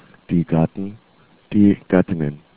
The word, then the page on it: gattin: german